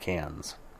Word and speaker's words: Cairns: I know in the notes it looks like it says Cairns, but it's pronounced Cairns.